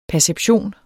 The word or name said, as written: perception